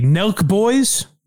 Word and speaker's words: Nelk Boys: So OJ was on with the Nelk Boys.